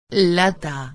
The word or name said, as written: lata